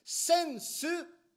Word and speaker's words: sensu: In Japanese, we call this sensu.